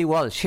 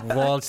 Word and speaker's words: Walsh: I think I said Walsh there, even though Welsh, we pronounce it Welsh back in Mayo as well.